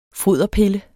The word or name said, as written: foderpille